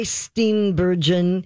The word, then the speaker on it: Steenburgen: First of all, all four stars Jane Fonda, Mary Steenburgen, mm-hmm.